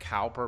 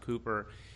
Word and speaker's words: Cowper: It looks like Cowper, but it's pronounced Cooper.